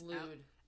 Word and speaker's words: Llwyd: I think it's Lude. It's Lude. – L-L-W-Y-D.